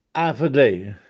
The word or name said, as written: Afedè